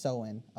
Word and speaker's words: Samhain: Halloween origins date back to the ancient Celtic festival of Samhain, pronounced Samhain. Oh, Samhain.